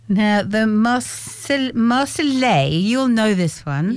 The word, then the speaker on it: Marseillaise: The Marseillaise. – Marseillaise. – Yeah. You don't say the L's are of silent. – No, it's a yes sound.